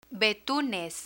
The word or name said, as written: betunes